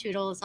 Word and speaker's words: Choodles: It's spelled C-H-O-W and Choodles, C-H-O-O-D-L-E-S. – So like noodles, but C-H instead, yeah, right? – Yeah, pero we feature...